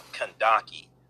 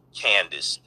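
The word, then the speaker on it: Kandake: Okay, now, the term Kandaki, which is also pronounced Candace, mispronounced Candace, but the term Kandaki, that means queen mother or queen regent.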